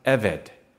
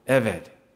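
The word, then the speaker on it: Eved (עבד): It is written in the Hebrew, E-B-E-D-Y-H-W-H. It's pronounced Eved, like a V. Eved.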